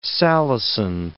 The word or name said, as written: salicin